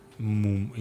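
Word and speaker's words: Múm: Ансамбль Мюм, правильно я произношу? – Мум. – Мум.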